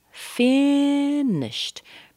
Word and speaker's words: finished: Finished. Be careful of the ED at the end. It's pronounced as a T because the SH is an unvoiced sound.